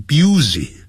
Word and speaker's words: busy: busy 不要念成 busy 啊， busy。